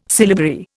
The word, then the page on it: syllabary: Indian